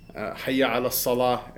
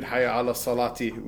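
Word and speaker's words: hayya 'ala s-salah: Hayya ala salah, you don't read hayya ala salati with the grammatical case ending. And so every one of the lines should be it should be pronounced separately, and it should be pronounced with a stop.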